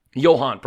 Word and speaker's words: Johann: I think it's pronounced Johann. – Johann, probably. Sorry, that was my mistake.